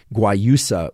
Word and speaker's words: guayusa: Some people who don't like mate might prefer something like guayusa, which is spelled G-U-A-Y-U-S-A. G-U-A-Y-U-S-A, guayusa, which is from Ecuador, despite the USA ending to it.